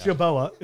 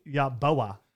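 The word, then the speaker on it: Yeboah: But what about Kenny Buyakasha? – Say what? – Yaboya? – Yaboa. – Yaboa. – It's Yaboa. It's pronounced Kenny Yaboa, Mississippi.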